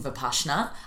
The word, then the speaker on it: Vipassana: But anyway, you got to remember, it's the shh in Vipassana.